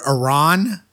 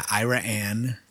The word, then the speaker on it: Iraan: So just imagine the word Iran, but with an extra A next to the A. And they pronounce it Iran.